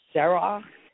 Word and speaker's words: Serach: The other one, you just write Serach, S-E-R-A-C-H. That's how it would be spelled phonetically in English.